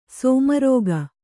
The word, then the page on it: ಸೋಮರಾಗ: kannada